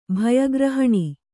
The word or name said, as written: ಭಯಗ್ರಹಣಿ